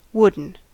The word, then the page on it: wooden: American English